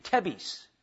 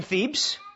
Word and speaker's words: Thebes: T-H-E-B-E-S. How do you pronounce that? T-H-E-B-E-S. Anybody? Thebes. Some know that. I was going to say Tebes. It's Thebes. T-H-E-E-B-Z. That would be a good way to pronounce it.